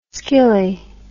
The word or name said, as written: skilly